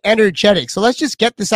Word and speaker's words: Energetic: How do you pronounce the name? – Energetic. – So it's a silent E. – Yes. – So the silent, so it's Energetic. Okay, I'm just making sure that I'm saying it right.